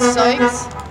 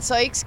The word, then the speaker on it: Sykes: My name is Carrie Ann Sykes. I've been an aide for 10 years. – Say your name again. – Carrie Ann Sykes, K-E-R-I-A-Y-N-S-Y-K-E-S.